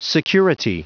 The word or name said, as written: security